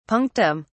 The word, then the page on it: punctum: British English